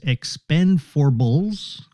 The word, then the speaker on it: Expend4bles: It looks like Expendables 4, or is what they're calling it, Expend4bles is the title. It's spelled EXPND4, the number 4, BLES, Expend4bles, okay? But it's Expendables 4.